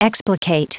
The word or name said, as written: explicate